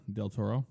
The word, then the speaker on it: del Toro: Guillermo del Toro? – Maybe. Del Toro? – Del Toro, yeah.